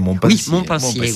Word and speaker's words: Montpensier: où nous apercevons le duc de Montpensier nous je le prononce bien, Montpensier. – Oui.